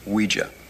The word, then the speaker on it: Ouija: It's pronounced Ouija, not Ouija. It comes from the French and German words for yes. Oui and ja. Ouija.